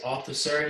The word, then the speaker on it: Althusser: Althusser? I'm saying his name wrong. His name is Althusser.